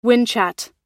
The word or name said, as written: whinchat